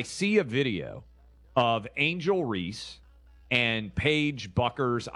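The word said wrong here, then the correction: Bueckers: Beckers, sorry. Paige Beckers. I always get that wrong.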